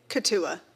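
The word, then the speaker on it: Keetoowah: How do you say the K? – Katua.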